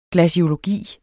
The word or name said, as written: glaciologi